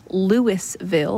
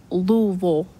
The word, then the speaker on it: Louisville: They, anything that has a vill at the end, they say vull, like Louisville, Kentucky. It looks like Louisville or Louisville, but Louisville, I don't know who came up with that.